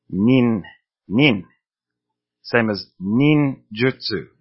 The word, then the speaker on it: ninjutsu: The next thing is the U in jutsu. It is never pronounced I. You see some people writing ninjutsu as ninjitsu, as a Brazilian jiu-jitsu.